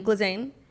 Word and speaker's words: Ghislaine: How do you pronounce her name? – Ghislaine. – Ghislaine.